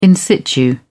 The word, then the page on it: in-situ: British English